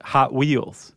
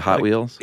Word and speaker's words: Hot Wheels: That's how they pronounce it, Hot Wheels. – Oh, instead of Hot Wheels? – Yeah. The emphasis is on the hot.